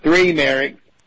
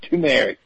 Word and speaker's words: turmeric: It's not three merrick. Not three merrick. It's turmeric. Turmeric.